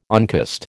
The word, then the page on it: oncost: American English